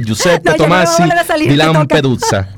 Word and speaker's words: Giuseppe Tomasi di Lampedusa: Pues el 23 de diciembre nace en 1896 Giuseppe Tomasi di Lampedusa, Escritor italiano. – Caramba, vale, pronuncia nuevamente. Giuseppe Tomasi di Lampedusa. Oye, te salió muy bien.